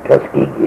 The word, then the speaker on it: Tuskegee: Yeah, Tuskegee, I think Tuskegee is right. Pronounce it.